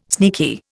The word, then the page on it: sneaky: Indian